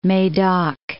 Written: Medoc

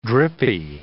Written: drippy

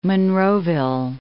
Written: Monroeville

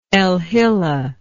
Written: alhillah

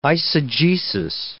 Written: eisegesis

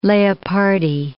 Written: Leopardi

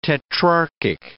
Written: tetrarchic

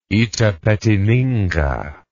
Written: Itapetininga

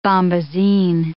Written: bombazine

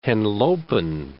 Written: Henlopen, Cape

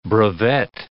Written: brevet

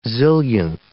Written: zillionth

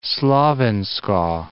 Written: Slovensko

